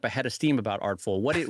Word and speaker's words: Artfol: So, Brad, switching topics, I wanted to ask you, because I know you had a lot of thoughts on it, Artful, the new social media platform specifically for artists. For those that are not familiar with it, it's spelled Artfol, which I guess is short for Artfolio.